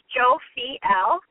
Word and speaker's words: Jophiel: I did a bit of investigative work, and I found that the name Jophiel, pronounced Jophiel, means beauty of God.